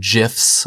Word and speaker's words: GIF: I know he posts some GIFs. I'm going to say GIF because that's... both what I've always said, and second, what the creator of the format said it's supposed to be pronounced.